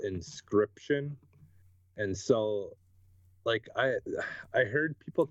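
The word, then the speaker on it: Inscryption: It's called Inscription. – Inscription. Okay, got it. – Yeah, it's spelt weird though. It's INSCYPTION. Yeah, it's like crypt, you know, like crypt.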